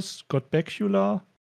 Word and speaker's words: Bakula: Scott Bakula, Scott Bakula. Ich, glaub, ich, ich glaube, das Bakula zweit, zweite ist richtig. – Bakula? – Bakula? Ich, glaube ja.